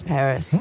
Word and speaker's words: Parius: Yes, but it's spelled P-A-R-I-U-S, Paris. She was a twin.